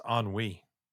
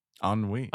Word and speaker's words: ennui: And it's actually pronounced ennui. – Ennui.